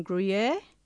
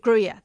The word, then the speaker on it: Gruyere: And the second one, which is also the Yarra Valley, is at 37, I can never pronounce this, you guys might be able to help me, Gruyere Road in Gruyere. – Gruyere, there we go. So it's Gruyere, Gruyere, and it's called Bentwood.